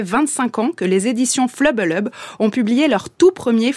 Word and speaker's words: FLBLB: Cette maison d'édition indépendante basée à Poitiers, qui publie des bandes dessinées et des romans photos, et dont le nom s'écrit FLBLB et se prononce donc Flubbelub ou Flubbele. C'est en gros le bruit que je vous ferais, Marie, si je vous faisais une grimace en vous tirant la langue.